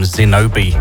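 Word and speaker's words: Xinobi: That first tune, brand new music out of Portugal from Zinobi with a Silent X.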